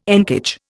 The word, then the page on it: encage: Indian